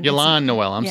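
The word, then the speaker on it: Y'lan Noel: And I know a lot of people haven't seen that movie, but Ian Noel or Yolan Noel. Sorry.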